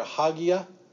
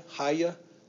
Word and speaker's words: hagia: Okay, if I use just the first part, hagia, or hagia, haya, it's pronounced a little different ways. I think if you're English, you got to put that G in there a little bit.